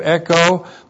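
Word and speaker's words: Echo (ἔχω): This is the present active indicative of echo, plus the accusative singular paresia, plus the prepositional phrase pros, plus the accusative singular of theos, with the definite article. The verb echo E-C-H long O. Echo.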